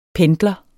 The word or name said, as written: Pendler